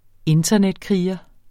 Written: internetkriger

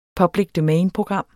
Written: public domain-program

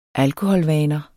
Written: alkoholvaner

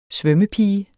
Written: svømmepige

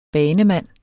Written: BANEMANDENS

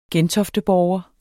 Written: gentofteborger

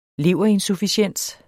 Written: leverinsufficiens

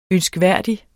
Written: ønskværdig

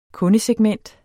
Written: kundesegment

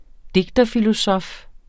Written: digterfilosof

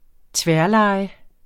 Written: tværleje

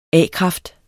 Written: a-kraft

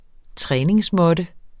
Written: træningsmåtte

